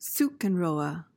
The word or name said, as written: zugunruhe